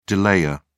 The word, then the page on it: delayer: British English